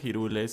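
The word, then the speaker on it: Hyrule: Bienvenidos a un nuevo episodio de Ikigai Podcast, el mejor show de todo el reino de Hirule, porque se pronuncia Hirule según el mismo eh, Nintendo.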